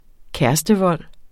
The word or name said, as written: kærestevold